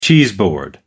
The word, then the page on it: cheeseboard: American English